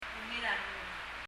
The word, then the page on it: ungil a rengul: [ŋil ə rɛŋəl]